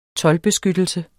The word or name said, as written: toldbeskyttelse